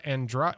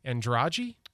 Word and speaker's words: Andrade: She finished behind Brazil's Rebecca Andrade, which I think they say Andrade. – I think it's Andrade. – That's how they're pronouncing it on the television. – Locals around here want to say Andrade.